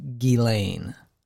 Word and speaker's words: Ghislaine: I believe the correct pronunciation is Ghislaine.